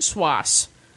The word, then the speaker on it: Swass: From the album Swass, which may be pronounced Swass, to rhyme with boss.